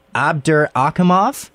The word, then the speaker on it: Abdurakhimov: Abdurakhimov.